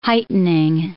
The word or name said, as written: heightening